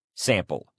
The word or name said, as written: sample